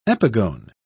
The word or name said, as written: epigone